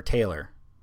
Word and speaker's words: Taylor: referring to Breonna Tyler, or Taylor.